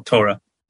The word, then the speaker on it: Torah: Is it pronounced Torah with the emphasis on the second syllable? – I think it's the first syllable, Torah.